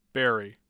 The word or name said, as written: barry